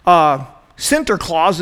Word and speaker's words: Sinterklaas: So this guy named St. Nicholas, or Sinterklaas is how they say it in Dutch, is a real guy.